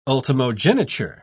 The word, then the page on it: ultimogeniture: British English